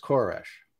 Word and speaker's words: Koresh: And so Cyrus, historically, is an ancient king of Persia, but the Hebrew pronunciation is Koresh.